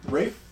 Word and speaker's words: Ralph: Maybe an English pronunciation of that spelling, Rafe. And you know what? Just sounds better than Ralph.